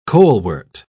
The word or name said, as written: colewort